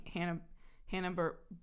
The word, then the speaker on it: Hanna-Barbera: Hanna-Barbera. – Yeah. – Right, is that how you pronounce it? – Yeah.